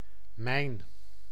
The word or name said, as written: mijn